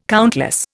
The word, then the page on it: countless: Indian